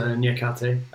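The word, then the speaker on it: Niakhaté: They've gone for a defender, Moussa Niakate. Is that the right way to pronounce him? – Yeah, so Niakate.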